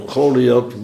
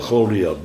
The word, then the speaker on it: Goliath: En dat laatste, die laatste T of TH aan het eind van Goliath, dat wordt ook uitgesproken als een S. Het is de Engelse TH eigenlijk.